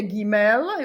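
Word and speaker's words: gimel (גימל): Tak, potom je gimel, jo? Gimel je g. Biblická hebrejština nemá nic jako dž, jo? Prosím vás, to má už jenom moderní hebrejština, jo?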